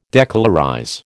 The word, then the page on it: decolorize: American English